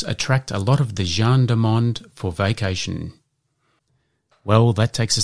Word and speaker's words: gens du monde: And this week's Word of the Week is Gendarmonde. And that is spelt G-E-N-S-D-U-M-O-N-D-E.